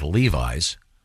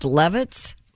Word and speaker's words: Levis: But Mr. Levis, who spells his name, by the way, just like Levi's. – But it's pronounced Levitz? – Levis.